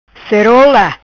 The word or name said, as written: Cerola